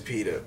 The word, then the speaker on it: Peeta: Yeah, it is PETA, bro. – It's P-I-T-A? – P-E-E-T-A.